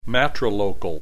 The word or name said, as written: matrilocal